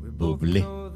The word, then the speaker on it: Bublé: Se llama Michael Bublé. B-U-B-L-E, con acento en la E.